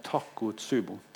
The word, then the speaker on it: Takotsubo: Nei, Takotsubo.